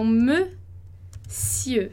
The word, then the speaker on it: monsieur: Então, monsieur. A gente vai dizer ali como você está visualizando, né, entre barras, monsieur. Então, esse e fechado ali, e, monsieur. Como Montesquieu, aquele escritor, né, filósofo francês, Montesquieu, monsieur, sieur, ieu, tá?